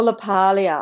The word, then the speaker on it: LaPaglia: Or LaPaglia. I don't think you'd pronounce the G.